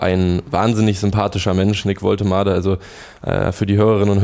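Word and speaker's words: Woltemade: Waltermade oder so, aber Waltermade. Er wird wirklich Waltermade ausgesprochen.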